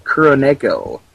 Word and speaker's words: Kuroneko: It's a 1968 Japanese movie called Kuroneko. It's spelled K-U-R, O-N-E-K-O.